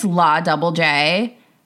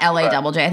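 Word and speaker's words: La DoubleJ: And I would also buy, I don't know how to say the name of this brand, but it's like, I think it's La Double J sometimes, not LA Double J.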